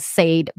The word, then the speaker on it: Sade: Sade. His last name is Sade. It's spelled as Sade, but it's pronounced in French as Marquis de Sade.